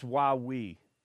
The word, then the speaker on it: Huawei: It's Huawei, like you would say when you were five and you were going down a slide and you'd say, Huawei!